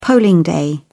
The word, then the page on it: polling day: British English